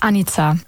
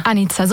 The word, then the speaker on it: Anica: Ganz perfekt ist Anica. – Anica. – Anica, so wie Annika, aber mit Z ausgesprochen.